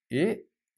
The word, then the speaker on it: Et: Et vous êtes employé, ici, féminin, un E. E. Ok, d'accord. Et vous? Et vous? Et? So remember the T is silent here.